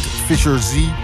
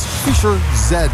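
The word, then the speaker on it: Fischer-Z: Ik zei vroeger ook altijd Fisher Z. Ik weet ook niet waarom. Maar het is Fisher Z.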